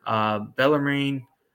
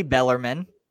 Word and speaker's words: Bellarmine: North Alabama, Alabama State again, Bellarmine, pronounce it correctly, Bellarmine.